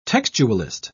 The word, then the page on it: textualist: British English